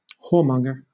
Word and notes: whoremonger: British English

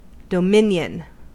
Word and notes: dominion: American English

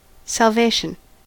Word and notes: salvation: American English

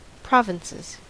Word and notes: provinces: American English

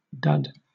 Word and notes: dud: British English

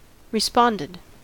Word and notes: responded: American English

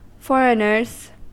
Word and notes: foreigners: American English

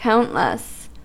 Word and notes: countless: American English

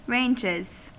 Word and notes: ranges: American English

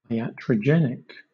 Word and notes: iatrogenic: British English

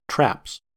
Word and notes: traps: American English

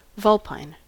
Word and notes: vulpine: American English

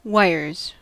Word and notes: wires: American English

